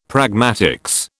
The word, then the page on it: pragmatics: British English